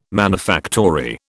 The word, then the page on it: manufactory: British English